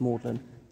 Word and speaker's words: Magdalen: Magdalen, I know it looks like Magdalen, pronounced Magdalen.